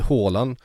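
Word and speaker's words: Haaland: Då får du Håland. Och då kommer det vara typ rätt. – Och sen är väl på norska är väl det nästan tyst, va? – Ja, det blir Håland.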